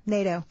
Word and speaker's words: Nadeau: Sounds like Play-Doh. – Yeah. – NATO. It is spelled NADEAU Chicago.